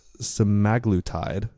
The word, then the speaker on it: semaglutide: Semaglutide.